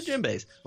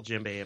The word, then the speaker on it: djembe: Well, anyway, how you spell djembe apparently it starts with a D. But it's like Django Unchained. It's like, how do you spell that? Well, starts with a D. D is silent.